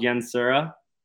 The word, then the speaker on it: Yansura: Gosh, I've been told Yansura, like with the kind of sounds like with the E, but spelled with an A.